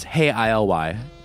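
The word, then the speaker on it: Hey ILY: The album they released is called Psychokinetic Love Songs, and the way the band usually spells their name is Hey I-L-Y. You know, I pronounced it Hey I Love You, but it's Hey I-L-Y.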